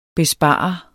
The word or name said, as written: bespare